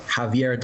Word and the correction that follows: Xavier: Dolan. Is that how you pronounce his name? – Uh, so the thing is, Zav Xavier Xavier Dolan, but close, very close. – So yeah, it's my explanation. to a Z sound.